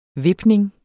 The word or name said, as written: vipning